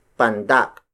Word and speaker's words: pandak: The opposite of tangkad is pandak. Pandak. Pandak. Pandak is spelled as P-A-N-D-A-K.